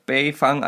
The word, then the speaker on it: Bayfang: Genau, bei Bayfang, also B-A-Y Fang Flyfishing.